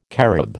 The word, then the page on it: carob: American English